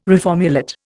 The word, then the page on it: reformulate: Indian